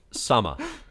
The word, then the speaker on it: Sama: Kaguya, which is close enough, but you just said Sami or something? It's Sama. – Yeah, that was S-A-M-A. That one's not that hard. – Sama.